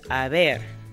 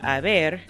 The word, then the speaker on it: haber: However, as you know, in Spanish, the H is not pronounced. It is silent unless the letter C appears before it. So haber is actually spelled H-A-B-E-R.